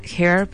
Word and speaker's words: H.E.R.: Ellos se llaman Hair, pero se escribe h.e.r.